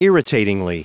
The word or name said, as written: irritatingly